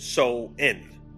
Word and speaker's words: Samhain: Let's start with the usual suspect, Samhain, pronounced So-in.